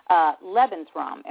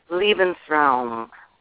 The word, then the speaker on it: Lebensraum: And you did an Israel Horovitz play, Lebensraum. Am I pronouncing that correctly? – That's right. Lebensraum.